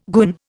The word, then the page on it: goon: Indian